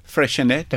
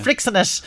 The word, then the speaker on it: Freixenet: No, I always thought, and I always pronounced it wrong. Say it again. – Freixenet. – Okay. And I used to call it Freixenet. But I thought that was Spanish.